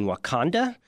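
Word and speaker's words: Wauconda: in Wakanda, which is not the Black Panther movie, but they pronounce it the same way.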